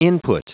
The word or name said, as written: input